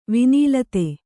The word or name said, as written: ವನಲತೆ